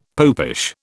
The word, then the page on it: popish: British English